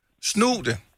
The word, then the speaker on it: snutte: Snute? – Snute.